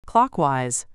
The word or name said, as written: clockwise